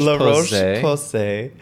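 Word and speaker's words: La Roche-Posay: La Roche La Roche Posay is um,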